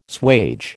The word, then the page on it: swage: American English